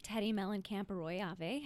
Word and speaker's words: Teddi Mellencamp Arroyave: So I'm here with Teddi Mellencamp Arroyave. How are you? I'm good. Look at that pronunciation. I practiced.